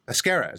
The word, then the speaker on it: Pesquera: Pesquera.